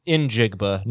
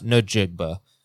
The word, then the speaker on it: Njigba: It is Njigba, not Njigba.